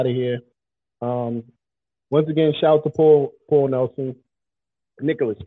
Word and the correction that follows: Nichols: And it's not Nicholas. Isn't it Paul Nichols? – Nichols.